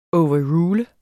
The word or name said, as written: overrule